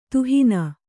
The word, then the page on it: ದಹನ: dahana